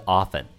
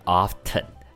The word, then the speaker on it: often: 美国人呢，大部分会念的是 often，那英国呢则会念 often。